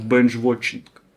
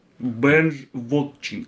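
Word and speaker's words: binge watching: последнее, которое я вам сегодня навяжу, есть такое слово, э, которое ну, читается как бенч watching, бенж watching.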